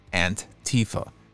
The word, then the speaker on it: Antifa: Antifa, with emphasis on the first syllable, which sounds like an in English, then an.